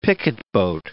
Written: picketboat

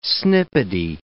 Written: snippety

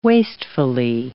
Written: wastefully

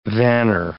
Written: vanner

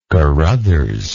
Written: Carothers

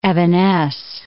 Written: evanesce

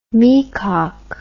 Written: meacock

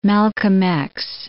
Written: Malcolm X

